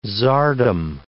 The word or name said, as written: czardom